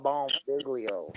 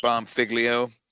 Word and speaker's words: Bonfiglio: What do you say, Mr. It's Bomb Figliotti? – No, Bomb Figlio. – How do you spell that, sir? – B-O-N-F-I-G-L-I-O. Yeah, baby. – But Mr. Bomb Figlio.